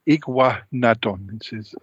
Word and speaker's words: Iguanodon: Iguanodon. That's how you pronounce it.